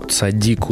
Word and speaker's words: цадик: Вот Лана из Израиля меня исправляет, что цадик ударение, кажется, цадиков ударение на и. А я вот полез в Залезняка смотреть, и у него цадик везде. Но вам с иврита, наверное, виднее. Не знаю, в общем, цадик или цадики.